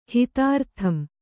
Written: ಹಿತಾರ್ಥಂ

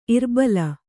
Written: ಇರ್ಬಲ

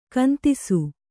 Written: ಕಂದಿಸು

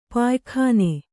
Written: ಪಯ್ಕನೆ

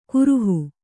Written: ಕರಹ

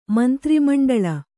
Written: ಮಂತ್ರಿಮಂಡಳ

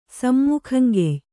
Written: ಸಮ್ಮುಖಂಗೆಯ್

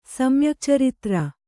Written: ಸಮ್ಯಕ್ಚರಿತ್ರ

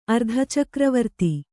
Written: ಅರ್ಧಚಕ್ರವರ್ತಿ

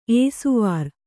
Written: ಏಸುವಾರ್

